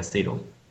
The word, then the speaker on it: Sedo: And there's a bit of a debate everywhere if you pronounce it Sedo or Sedo. Mm-hmm. And the thing is, kind of, we even internally use both pronunciations. In English, we would always say Sedo. In most European countries, people say Sedo.